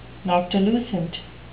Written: noctilucent

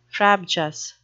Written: frabjous

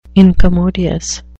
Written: incommodious

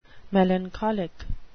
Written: melancholic

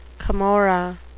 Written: camorra

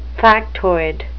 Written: factoid

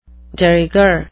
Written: de rigueur

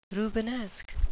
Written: rubenesque

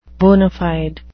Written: bona fide

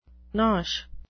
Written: nosh

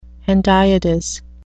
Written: hendiadys

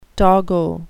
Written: doggo

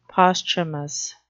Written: posthumous

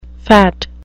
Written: phat

